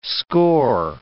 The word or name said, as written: score